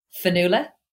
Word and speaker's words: Fionnuala: Fionnuala. – Fionnuala. – So it's spelled Fionnuala. – Fionnuala. – So it's a Gaelic name, Fionnuala. Yeah.